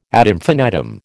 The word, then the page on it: ad infinitum: American English